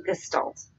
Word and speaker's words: gestalt: you here, because it's not gestalt. – Gestalt? – It's gestalt.